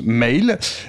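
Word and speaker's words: M(AOL): Alors ça s'écrit MH entre parenthèses. A-O-L, et on me dit que ça se prononce Mail, et donc Mail vient de sortir un album, s'appelle Something Soft, et Cédric nous a sélectionné un premier extrait, Poursuite.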